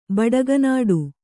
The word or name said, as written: ಬಡಗನಾಡು